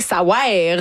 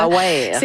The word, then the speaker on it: Ça wear: Ça veut rien, ça wear. Vraiment, ça s'écrit de même, il faut le prononcer comme ça. – Ça wear.